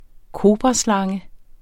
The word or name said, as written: kobraslange